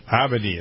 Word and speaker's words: Abedi: How do you pronounce that? – Abedi is what I've been saying.